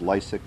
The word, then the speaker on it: Lysek: That's spelled Z-A-C-H-A-R-Y. Last name is Lysak. That's spelled L-Y-S-E-K.